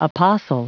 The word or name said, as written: apostle